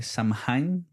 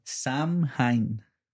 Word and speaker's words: Samhain: Se escribe S-A-M-H-A-I-N. Y espérate, dímelo más lento. Samhain. Y, de pero, me deletréamelo más lento. S-A-M-H-A-I-N. H-A-I-N. – Samhain, algo así. Ok. Vamos a decir que así se pronuncia.